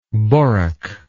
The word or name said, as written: borak